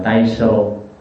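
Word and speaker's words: Taishō: Đại Chánh tiếng Nhật đọc là Tây Sô,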